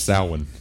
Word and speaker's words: Samhain: It's pronounced Samhain. – Oh. Sorry. My Celtic studies aren't up to date. – You can say Samhain.